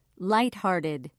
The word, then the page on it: lighthearted: British English